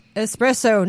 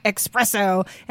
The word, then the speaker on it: espresso: He said it's pronounced espresso, not expresso.